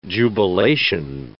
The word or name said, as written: jubilation